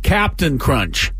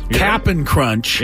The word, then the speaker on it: Cap'n Crunch: And by the way, I'm pronouncing that correctly. It is not Captain Crunch. – Cap'n. – It's Cap apostrophe N. – It is. – Look at the box. – It is. – Cap'n Crunch.